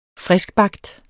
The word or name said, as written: FRISKBAGT